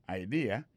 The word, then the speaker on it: idea: Alors, accent vient tomber dans la deuxième syllabe là. One idea. Alors, moi, c'est idée. Vous faites trois syllabes vraiment. IDEA. On dit grand mot comme ça, il y a trois syllabes là-dedans, mes amis.